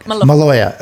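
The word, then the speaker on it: Maloja: Maloya. Okay. I want to have like a hard J in that pronunciation, but okay.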